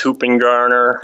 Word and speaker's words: Hoopingarner: people did not want to say or pronounce Hoopingarner. – Yeah. – As you can imagine, it's been pronounced 100 different ways.